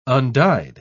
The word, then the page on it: undyed: British English